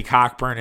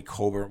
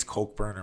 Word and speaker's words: Cockburn: I think it's Kofi Coburn, whatever. His name is spelled like Cockburn. If your last name looks like Cockburn, you're Cockburn. I think it's Cokeburn or whatever.